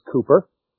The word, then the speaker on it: Cowper: All right, today we are looking at William Cooper, who his name is spelled like Cowper, but the English pronunciation is Cooper.